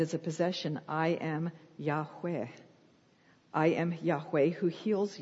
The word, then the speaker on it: Yahweh: Okay, we say Yahweh in our American accent, Yahweh, but it should be pronounced closer to Yahweh. So it's more like a breathy sound.